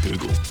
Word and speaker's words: googol: G-O-O-G-O-L. Google. – I've never heard it in my life. – Hang on, we've got a clarification. – You've pretty much nailed it. It's just pronounced like Google.